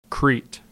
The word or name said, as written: Crete